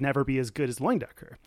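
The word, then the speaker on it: Leyendecker: Loindecker. – I even looked up the pronunciation because it's spelled like something completely different.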